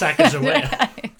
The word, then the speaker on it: Sacagawea: Or they'll just say you don't know how to pronounce Sacagawea. They're like, why did you say it like that? – And then you can explain that on this podcast, you found out it's a hard G.